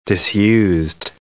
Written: disused

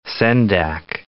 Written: Sendak

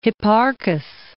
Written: Hipparchos

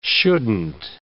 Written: shouldn't

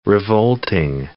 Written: revolting

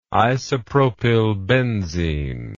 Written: isopropylbenzene

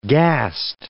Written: gast

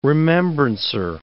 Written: remembrancer

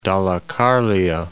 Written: Dalecarlia

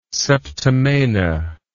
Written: septimana

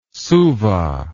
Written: Suva

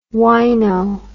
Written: huayno

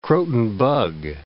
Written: Croton bug

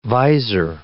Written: visor

